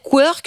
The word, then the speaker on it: Querc: J'ai dit Querc, ça se dit peut-être Querc, je sais. – Ça se dit Querc, c'est la prononciation était parfaite.